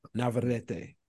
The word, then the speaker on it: Navarrete: How do you say it again? – Navarrete.